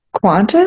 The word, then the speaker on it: Qantas: Qantas? – Qantas, Qantas. There's no U.